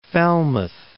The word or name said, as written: Falmouth